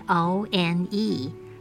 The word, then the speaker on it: one: 英文中并没有国语“ 安” 的发音，那要如何发出这个字呢？One, one，拼字是 O N E，凭空蹦出一个 W 的音，O 的母音发的是 a a n 发嗯，字尾移不发音，整个字是 one one。